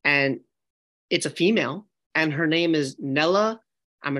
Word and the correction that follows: Nila: The last name is pretty close, I feel like. But I think her first name is Nila. – Nila.